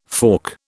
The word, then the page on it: fork: British English